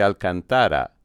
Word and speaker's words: Alcantara: It's simply Alcantara, and must be pronounced emphasizing the second-to-last or third syllable, whether or not you're pronouncing it in Castilian, English, Italian, or Portuguese.